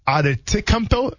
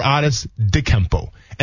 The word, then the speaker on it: Antetokounmpo: And you can call him, for those of you that don't know, you can call him Giannis Antetokounmpo or Antetokounmpo. And the D is actually, since he's from Greece, they pronounce it with a D, the way he spelt his name. Or you can pronounce it with a T.